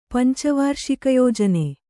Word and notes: ಪಂಚವಾರ್ಷಿಕಯೋಜನೆ: panca v ārṣikayojane

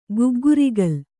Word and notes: ಗಗ್ಗರಿಗೋಲು: Kannada

